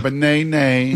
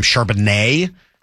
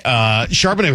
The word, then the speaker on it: Charbonnet: Well, whoever it was that was calling that game kept calling him Charbonnet, which was driving me nuts watching the replay of it last night. But whatever. Like, it just, you got the emphasis on the wrong syllable.